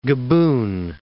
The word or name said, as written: Gabun